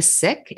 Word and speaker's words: Sikh: And I'm a Sikh. It's spelled S-I-K-H, pronounced Sikh, not Sikh, as we decolonize language.